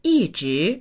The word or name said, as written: yi4zhi2